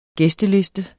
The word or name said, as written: gæsteliste